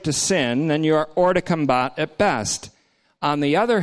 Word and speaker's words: hors de combat: If you're an obedient slave to sin, on the other hand, you are, French term, hors de combat, that's how it's pronounced, hors de combat. Doesn't mean whores of combat.